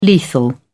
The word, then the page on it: lethal: British English